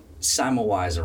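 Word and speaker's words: Semmelweis: So you've published a number of articles on this topic, and you've referred to a physician from, I believe, when was Simelwise around? And am I pronouncing that correctly? – Yes.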